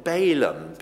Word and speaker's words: Balaam: The land of Canaan, C-A-N-A-A-N, Canaan, and the prophet Balaam, B-A-L-A-A-M, in Numbers 23.